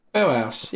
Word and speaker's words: Belhus: Can you just say it properly once? It's spelt. – B-E-L-H-U-S. Bellas. – Bellhouse. Yeah, Bellhouse.